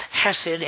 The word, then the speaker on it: hesed: It's the word, it's pronounced hesed, and it's spelled H-E-S-E-D, and it means an unchanging, loyal love, love that never changes.